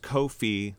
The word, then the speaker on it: Ko-fi: So it makes you think it's called coffee. But I looked it up and it's Ko-fi, like no fee is how it's pronounced, because they say that they don't take a fee.